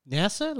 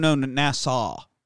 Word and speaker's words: Nassau: So now we cut to Nassau. Nassau in the Bahamas, which makes me think of the song Funky Nassau. – NASA? Like the rocket? – No, Nassau.